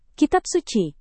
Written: kitab suci

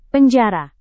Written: penjara

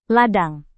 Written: ladang

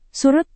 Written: surut

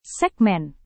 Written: segmen

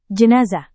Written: jenazah

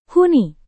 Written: huni

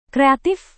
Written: kreatif

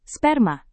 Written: sperma

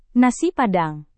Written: nasi padang

